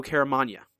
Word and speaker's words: Caramagna: I found out how to correctly pronounce Joe Caramagna's name. – Oh, very nice. – Joe Caramagna is how you pronounce that correctly.